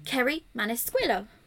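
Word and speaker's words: Kerri Maniscalco: Kerry Manisquillo, which is how they pronounce it on the audiobook, so I'm just going to stick with it.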